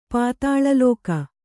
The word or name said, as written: ಪಾತಾಳಲೋಕ